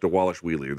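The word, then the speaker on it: Dvalishvili: I'm sure I'm fucking his name up, but they say on the broadcast, Dewalish Wheelie. There's a V there, but they pronounce it like a W.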